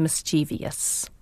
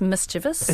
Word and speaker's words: mischievous: I have to tell you that my word bugbear is mischievous, which many people mispronounce as mischievous. – Hello? It isn't mischievous? – It's mischievous.